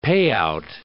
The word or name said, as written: payout